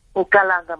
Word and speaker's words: Nkalanga: I think it's Dr. Kalamanga, am I saying his name right? – Nkalanga, yes. – Nkalanga, my apologies.